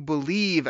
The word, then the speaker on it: BLEAV: We're going to talk all about the Detroit Lions and make sure that you believe as we head into 2020, the NFL draft, free agency, all types of things.